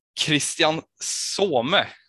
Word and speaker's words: Christian Soume: Och med oss har vi också eh, Christian Some. Eh, uttalar jag rätt då? – Absolut. Du får en tia på det.